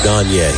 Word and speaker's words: Gaynier: Spells her name G-A-Y-N-I-E-R, but they say it's pronounced Gagne.